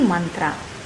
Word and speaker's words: mantra: Man is to be pronounced like that in Monday. And tra is the modified form of through. Tra. We must not pronounce mantra as mantra. We do not pronounce Monday as Monday. And we do not pronounce through as true. So why should mantra be pronounced as mantra?